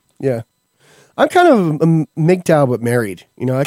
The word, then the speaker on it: MGTOW: Is it MGTOW? How do you say it, MK? Men going their own way? – Oh, yeah, MGTOW.